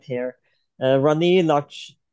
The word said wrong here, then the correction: Lakshmibai: Rani Lakshmibai of Jhansi. – Yes. Thank you for correcting me there.